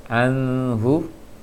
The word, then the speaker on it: anhu: Pengucapan huruf nun sukun harus tegas dia, jangan disamarkan. Anhu seharusnya jelas, karena bacaan nun sukun yang tidak dengung harus jelas dia.